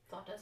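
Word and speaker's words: flautas: Last time, the first time we had it, uh, the first time I had it at least, I got the flotas because I really like that. Am I saying that right? Flotas. Flotas, yeah.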